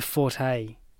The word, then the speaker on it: forte: It's not my forte. – Mm-hmm. – If you're discussing someone's forte, as in a strength, the E is silent. Forte is correct only if you're using it as a musical term.